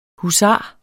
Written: husar

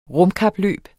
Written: rumkapløb

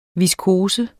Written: viskose

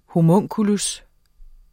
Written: homunculus

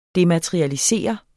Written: dematerialisere

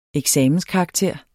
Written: eksamenskarakter

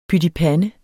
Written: pytipande

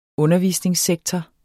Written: undervisningssektor

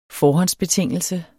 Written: forhåndsbetingelse

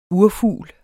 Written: urfugl

